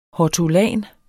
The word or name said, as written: hortulan